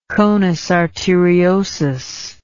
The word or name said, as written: conus arteriosus